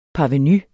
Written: parvenus

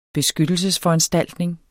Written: beskyttelsesforanstaltning

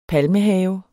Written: palmehave

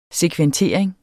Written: sekventering

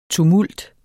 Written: TUMULTERNES

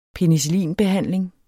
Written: penicillinbehandling

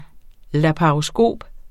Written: laparoskop

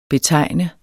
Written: BETEGN

